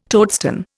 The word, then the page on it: toadstone: Indian